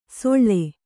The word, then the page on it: ಸೊಳ್ಳೆ: soḷḷe